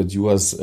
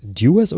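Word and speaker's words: Dewar's: Also wir haben auch so ein bisschen dieses Dewars-Programm, wo wir sagen so, also do it aus dem Englischen, und so wurde mir das erklärt, dass das so in die Richtung der Aussprache geht. Dewars, das ist mit Sicherheit beides nicht wirklich falsch.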